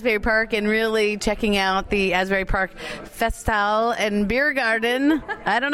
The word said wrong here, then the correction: Festhalle: Think of it like this Festhalle. – Festhalle. – Right? Give me a holla. – Festhalle. Perfect. You're good.